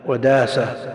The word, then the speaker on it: داسة: يقول كلمة ابن ماجه اسم صاحب السنة تنقط وتنطق تاء في الوصل أو هاء مع التوجيه، ماجه ومنده وداسة هي هاء لا تنقط ولا تنطق، ماجه ومنده وداسة كلها بالهاء. يقول